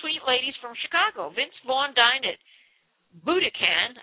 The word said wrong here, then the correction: Buddakan: I don't know if that's the proper pronunciation. It's Buddakan with friends on Saturday night.